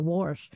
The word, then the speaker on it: Warsh: Well, see, and I just kind of said it wrong myself. I didn't say enough R there, Warsh. – Yeah, but an R in there.